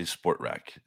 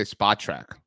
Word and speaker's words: Spotrac: A lot of people say Sportrac, and it's not Sportrac. – How could you say I think some people say Spotrac, but it's Spotrac.